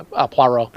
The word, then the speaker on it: Poirot: Yeah, Poirot. It's pronounced Poirot.